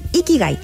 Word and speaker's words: Ikigai: Ikigai se escribe con ilatina. – Cada kilo y latino G-A-I. Ikigai, tal cual suena.